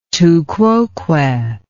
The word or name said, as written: tu quoque